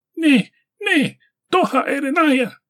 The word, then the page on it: Ne-ne-Doha-erenaya: Galindri